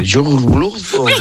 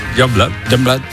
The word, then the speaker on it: Youngblood: Es que era muy difícil de pronunciar, pero luego ¿cómo se pronuncia? – Youngblood. – Ah, vale, pero ya está. – Youngblood. Bueno, pues eso es lo que yo quería decir, Youngblood.